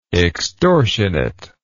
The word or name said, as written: extortionately